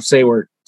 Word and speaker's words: Saewert: I'm sorry if I butchered your name there, but is that pretty close to right? – Yeah, close enough. Saewert. – There we go. Saewert.